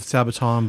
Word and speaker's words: Sabaton: Which they are very much in the style of Sabaton, but to another level, I think.